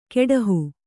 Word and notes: ಕೆಡಹು: keḍahu

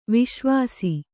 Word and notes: ವಸ್ಸಾವಾಸ: vassāvāsa

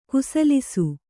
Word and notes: ಕುಸಲಿಸು: kusalisu

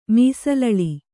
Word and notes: ಮೀಸಲಳಿ: mīsalaḷi

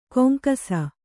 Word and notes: ಗುಂಗಿಸು: guŋgisu

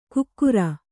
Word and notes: ಕುಕ್ಕುರ: kukkura

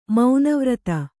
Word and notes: ಮನೋವಿರತಿ: manōvirati

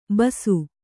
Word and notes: ಬೂಸಿ: Kannada

